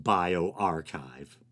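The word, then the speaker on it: bioRxiv: And it's funny, it looks like bio R and then some Roman numerals, but I looked it up and apparently it's pronounced BioArchive.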